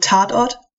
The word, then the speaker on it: Tatort: He was on German TV shows Derek and, I'm going to butcher this, to Tort. – Do you want me to say it? – Yes, please. – So that TV show is called Tatort, which is basically just crime scene in German.